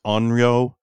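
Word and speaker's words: Onryo (怨霊): I think it's pronounced Onryo. – Yeah. – O-N-R-Y-O.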